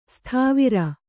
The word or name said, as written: ಸತ್ವರ